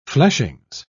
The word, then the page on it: fleshings: British English